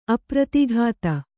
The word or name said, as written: ಅಪ್ರತಿಘಾತ